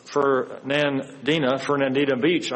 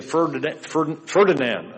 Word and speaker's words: Fernandina Beach: I talked to a new person there in Fernandina Beach. I have to pronounce it because I want to say Ferdinand.